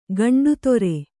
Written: ಗಂಡುತೊರೆ

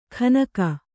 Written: ಖನಕ

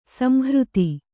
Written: ಸಂಹೃತ

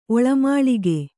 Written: ಒಳಮಾಳಿಗೆ

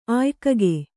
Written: ಆಯ್ಕಗೆ

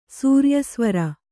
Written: ಸೂರ್ಯಸ್ವರ

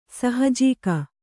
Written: ಸಹಜೀಕ

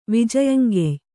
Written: ವಿಜಯಂಗೆಯ್

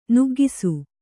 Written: ನಗೌಕಸ

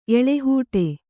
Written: ಎಳಹೂಟಿ

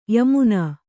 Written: ಯಮನ

